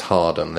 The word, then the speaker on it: Howarden: Well, it's written Howarden, but pronounced Harden.